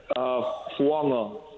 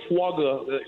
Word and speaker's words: Fuangwa: So there is a silent G in there, Fuangwa. – Yeah, Fuanga. Or you can say Fuaga. For some people, it's hard to say the G part.